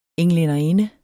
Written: englænderinde

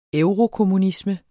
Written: eurokommunisme

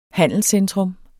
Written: handelscentrum

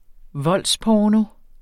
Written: voldsporno

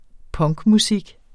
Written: punkmusik

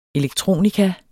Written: elektronika

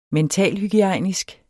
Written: mentalhygiejnisk

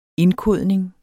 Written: indkodning

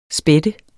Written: spætte

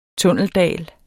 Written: tunneldal

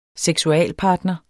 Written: seksualpartner

